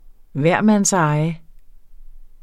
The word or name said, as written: hvermandseje